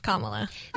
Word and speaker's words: Kamala: It's the word comma, like a period, dot dash, comma, la, Kamala.